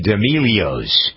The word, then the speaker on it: D'Amelio: So now some of Doug's Dirt features a family called the D'Amelios. Who are these people? The D'Amelios, is that how you pronounce it? – D'Amelio, and they're like the new Kardashians.